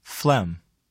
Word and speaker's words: phlegm: The letter G is not pronounced in the following words. Read them to note their spelling and repeat them during the pauses. – Phlegm.